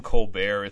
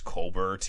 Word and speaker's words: Colbert: I remember he did, you know, the only funny joke he ever made was that he said his name isn't Colbert, it's Colbert. He just pronounces it French, so he sounds more interesting.